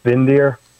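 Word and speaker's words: Windir: I think it's pronounced Vindir, but it starts with a W, W-I-N-D-I-R.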